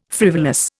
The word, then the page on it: frivolous: Indian